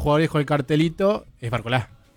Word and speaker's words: Barcola: ¿Por qué decís Barcola y no Barcola? – Sí, porque creo que la pronunciación es así, nos lleva tilde. Ah. En este caso, sí, Mbappé, por ejemplo. Y bueno, y sí, es Barcola.